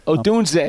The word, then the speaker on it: Odunze: Odunze.